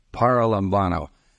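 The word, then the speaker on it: paralambanō: And how is it pronounced? Paralambano. I just had the short sounds there instead of the long ones with a head.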